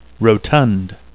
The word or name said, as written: rotund